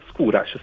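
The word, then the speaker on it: Skóraś: Quindi si pronuncia Skouras, non Skouras. – Skouras, sì. – Ah, ok, no, perché sai, il polacco è sempre scivoloso con le pronunce, quindi anche per noi è importante.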